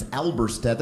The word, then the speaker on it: Alberstadt: My last name is Alberstadt. That's a German name. There's a silent D in there.